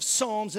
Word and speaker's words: Psalms: Now, the book of Psalms in the Old Testament starts with a P, so it'll throw you off if you're trying to learn how to spell it.